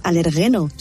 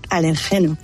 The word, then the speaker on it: alérgeno: En quiero decirle a Carlos que no se dice alergeno, se dice alergeno.